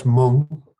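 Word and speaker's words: Mwng: And yeah, the album would be pronounced Mung.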